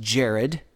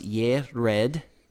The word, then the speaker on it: Jared: Now, in the King James Version, this looks like it should be pronounced Jared. But it comes from Strong's Concordance number 3382. And it is pronounced Yered.